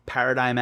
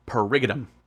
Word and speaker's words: paradigm: You know what word I always read paradigm as? Perigidum.